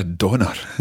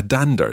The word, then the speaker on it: dander: And this means to go for a wander, to go for a walk, or to go for a donner, as we say in Scottish. – A donner. – A donner, or a dander, it's spelt dander.